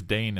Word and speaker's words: deign: But you did use Dane in that. – You did. – Then I would Dane. Ooh. – That was well done. – Well, that's at home. That's spelled D-E-I-G-N.